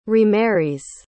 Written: remarries

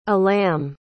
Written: alam

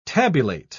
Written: tabulate

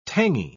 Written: tangy

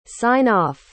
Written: sign-off

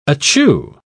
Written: achoo

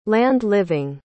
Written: land-living

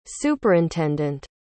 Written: superintendant

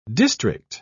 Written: district

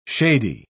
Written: shady

